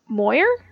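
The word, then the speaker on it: Moir: So the Canadian ice dancing team, Tessa Virtue and Scott Moyer.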